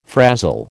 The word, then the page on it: frazil: American English